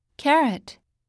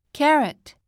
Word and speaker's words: carrot: Carrot Carrot T は息だけで T と発音しよう。